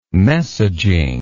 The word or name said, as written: messaging